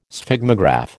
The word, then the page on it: Sphygmograph: American English